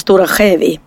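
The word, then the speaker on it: Stora Skedvi: eh, ja, men det, det stavas såhär Skedvi fast det uttalas Stora Skävi.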